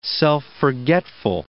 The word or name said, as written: self-forgetful